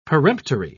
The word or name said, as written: peremptory